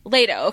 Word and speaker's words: Leto: She, even with her relationship with Leto, Leto, yeah. – Leto, okay, I said it right the first time.